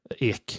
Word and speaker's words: Ek: Daniel Ek, eller Ek heter det, som vi alla kan på svenska.